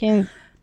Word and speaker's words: Cairns: Cairns.